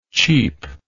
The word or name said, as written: cheapishly